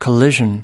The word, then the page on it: collision: American English